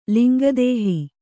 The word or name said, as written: ಲಿಂಗದಾಹ